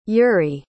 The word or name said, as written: yuriy